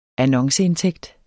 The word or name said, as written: annonceindtægt